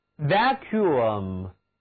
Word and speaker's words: vacuum: Vacuum 这个词里的两个字母 W 读作 U，请跟我读 Vacuum。